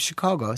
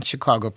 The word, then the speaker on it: Chicago: Now, people outside of Chicago say Chicago. That is not the way we in Chicago pronounce it. We pronounce it Chicago, which is more of an aw sound versus an ah sound.